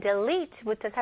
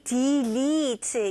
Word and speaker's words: delete: 근데 뭔가 e, delete 이런 느낌인 것 같은데 아닙니다. 발음은 d, i 발음. 첫 번째 음절은 d, i로 생각하세요. 그래서 delete가 보다는 d, 그 다음에 마지막은 e 발음이에요. delete, delete, delete.